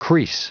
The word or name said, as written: crease